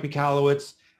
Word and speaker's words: Michalowicz: Yes, my name is Mike Michalowicz. No one can spell that name. I actually found out I was pronouncing it wrong from someone from Poland.